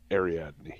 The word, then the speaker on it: Ariadne: Is it Ariadne? – Ariadne.